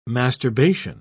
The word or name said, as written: masturbation